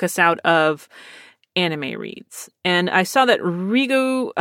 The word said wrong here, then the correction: Riho Sugiyama: uh, pardon me, I just butchered her name, Riho Sugiyama,